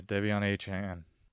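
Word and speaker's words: De'Von Achane: Devin Achane. Achane? I don't know how to say his name. Versus Denver. – Devion Achane. – Sorry, Devion Achane.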